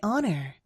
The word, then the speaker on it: honor: It is my honor. It is my honor. – 아시겠지만 이 H는 묵음입니다.